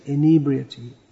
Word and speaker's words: inebriety: Prabhupada used to say inebriety. Inebriety. Dravida Prabhu just told me that. The standard pronunciation.